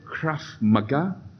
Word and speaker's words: Krav Maga: And so the activity he decided on was, how do you pronounce that? Krav Maga? Sounds like Japanese anime manga, but it's not.